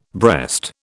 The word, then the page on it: breast: British English